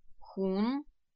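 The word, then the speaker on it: хун (红): Красный цвет произносится как «хум», «хум», красный, «хун».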